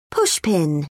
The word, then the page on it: pushpin: British English